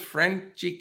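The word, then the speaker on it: Franchy: Yeah, so I think it is Franchi, but it's just they pronounce it with an accent. So interesting. All right, we'll stick with Franchi Cordero.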